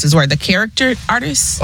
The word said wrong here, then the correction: caricature: Oh, caricature.